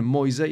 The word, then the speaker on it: Moise: Moise jak biblijny Mojżesz i z tym mamy to kojarzyć.